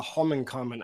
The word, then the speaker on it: Holmenkollen: I uh, first time I ever went to Norway, the client took me to the Holmenkollen. I don't know if I'm pronouncing that right, but I was yeah, that's right.